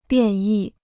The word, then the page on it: 变异: biàn yì